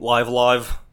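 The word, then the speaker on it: Live A Live: In smaller game news, I also tried, I'm going to say it wrong, Live Alive is correct.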